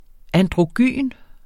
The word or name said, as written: androgyn